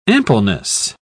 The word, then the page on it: ampleness: British English